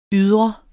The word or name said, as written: ydre